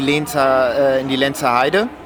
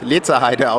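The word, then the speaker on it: Lenzerheide: Ich habe auch erstmal 3 Tage dort in den Interviews Lenzer Heide ausgesprochen. – Was haben wir gemacht? Waren wir Radfahren oder irgendwas? Du warst die ganze Zeit Lenzer Heide. Ich dachte, okay, er ist der Schweizer, ich werde ihn nicht korrigieren.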